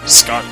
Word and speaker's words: Scott: You can find me, Scott Gardner, both on Twitter and Facebook. My name is spelled S-C-O-T-T.